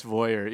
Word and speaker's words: voyeur: Voyeur? – Yeah. That's like the am I saying it right? – Yes, voyeur. Yeah.